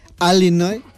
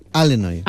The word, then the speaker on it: Illinois: ¿Sabes que hay, como aquí en España, hay acentos diferentes en Estados Unidos y los nativos del estado de Illinois lo pronuncian Illinois?